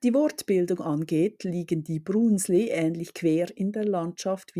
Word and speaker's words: Brunsli: Die schokoladehaltigen Brunsli, ausgesprochen mit langem U, Brunsli, werden nach ihrer Farbe benannt.